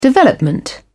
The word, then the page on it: development: British English